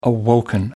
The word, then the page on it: awoken: American English